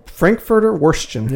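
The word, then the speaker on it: Frankfurter Würstchen: So it's called the Frankfurter Wurstchen. Not sure if I'm pronouncing that right. – Yeah. It sounds right. Say it again. – Frankfurter Wurstchen. – Yeah, sounds right. That's perfect.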